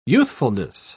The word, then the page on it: youthfulness: British English